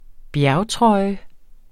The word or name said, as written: bjergtrøje